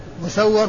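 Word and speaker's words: مُسَوَّر: مسور ومسور، مسور بضم الميم وفتح السين والواو المشددة، ومسور بالميم المكسورة والسين الساكنة والواو المفتوحة.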